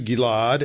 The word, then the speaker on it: Gilead: That's actually how it's pronounced in the Hebrew. It's also a name. He passed through Gilead and Menasheh, and passed through Mizpah of Gilead, and from Mizpah of Gilead he advanced towards the people of Ammon.